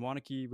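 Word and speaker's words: Waunakee: Wanakee, Wisconsin. How are you? – I'm great, and great pronunciation of my small town of Wanakee, Wisconsin.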